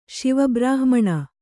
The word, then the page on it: ಶಿವಬ್ರಾಹ್ಮಣ: kannada